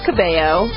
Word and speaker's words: Cabello: Well, I know that her last name is pronounced Cabello, but it is? – We'll just go with it.